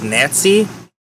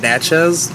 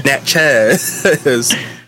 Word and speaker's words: Natchez: now, and Nats, almost, I keep pronouncing it as Natsy. It's called Natchez. Because when I first read it, in my head I was saying Nancy. And that's why I was Googling the pronunciation. I was like, oh, Natchez.